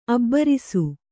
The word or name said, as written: ಅಬ್ಬರಿಸು